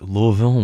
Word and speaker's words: Louisville: That's Louisville with two syllables, if you're pronouncing it correctly.